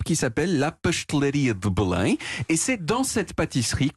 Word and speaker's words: Belém: Voilà, qu'on prononce en portugais Belém.